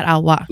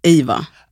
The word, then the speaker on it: Awa: Alltså jag skulle säga att om du skulle åka till typ USA, så hade de sagt Ava.